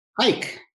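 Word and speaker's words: Huyck: I never pronounce his last name. I'm sorry? – Hike. – Willard Hike and Gloria Katz. Thank you.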